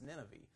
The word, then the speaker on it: Nineveh: If your Bible shows correct pronunciation, you'll notice that there, that it's actually an E on the end and not a It's Nineveh.